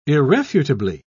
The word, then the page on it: irrefutably: British English